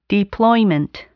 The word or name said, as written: deployment